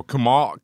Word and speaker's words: Kumail: Did you call him Kumar? What's it? – No, Kumal. Kumal. I do not know how to pronounce the name. – Okay. – K U M A I L.